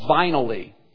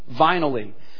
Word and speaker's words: Vinally: The name of the company is called Vinally, rhymes with finally, Vinally.